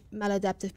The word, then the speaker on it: maladaptive: It was more of the behaviors, learning about behaviors, the functions of behavior, reinforcement, and how I can help change maladaptive behaviors with children. – Male adaptive? – Yes. – Male? – Maladaptive.